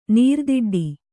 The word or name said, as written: ನೀರ್ದಿಡ್ಡಿ